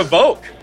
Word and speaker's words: Vouk: Today we have Ira Voke.